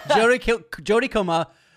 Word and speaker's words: Jodie Comer: Jodie Comer, yeah, that's how you say it.